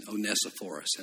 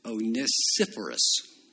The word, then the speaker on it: Onesiphorus: I have a habit of pronouncing Onesiphorus, and that's not accurate. The accurate, I'm told from Mr. Scorby, is Onesiphorus.